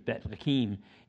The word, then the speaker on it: Bethlehem: Uh, in John, if you want to turn to that, chapter 6, And it's in- interesting because Bethlehem, if you're pronouncing it in the, in the Hebrew, it's Bethlehem, and it literally means house of bread.